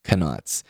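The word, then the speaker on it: Knautz: And that's how IMDb trivia says his name is pronounced, Connaughts.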